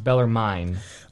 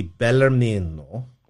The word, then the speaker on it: Bellarmino: Can you correct my pronunciation? Because even from the pulpit, I hear it pronounced Bellarmine. – Okay, so his name is properly Bellarmino.